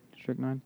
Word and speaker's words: Strychnine: Strychnine. – That's how you say it. Strychnine.